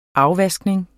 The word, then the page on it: afvaskning: ˈɑwˌvasgneŋ